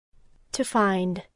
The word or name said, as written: discover